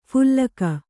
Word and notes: ಫಲ್ಗು: phalgu; Kannada

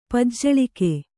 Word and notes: ಪಜ್ಜಳಿಕೆ: Kannada

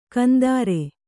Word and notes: ಕಂದಾರೆ: kannada

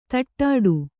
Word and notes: ತಟ್ಟಾಡು: taṭṭāḍu

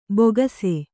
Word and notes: ಬೊಗಸೆ: bogase; Kannada

